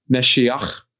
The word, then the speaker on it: Mashiach: Really, the Hebrew pronunciation of this word is Mashiach. Gotta get that, you know, hard German guttural sound in the back of your throat.